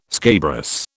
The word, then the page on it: scabrous: British English